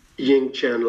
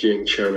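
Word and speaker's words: Yungchen: And Ying Chen Lamo, spelled Yung Chen, but it's pronounced Ying Chen, and she's an amazing Tibetan singer, Sounds great.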